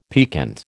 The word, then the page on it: piquant: American English